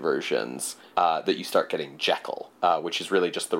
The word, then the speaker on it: Jekyll: It's also worth saying, even though we've been talking this whole episode, that nobody in 1920 probably would have said Jekyll. – Oh yeah, it's Jekyll. – Yeah, Jekyll is the pronunciation from the UK, where Robert Louis Stevenson was from. It's a Scottish last name.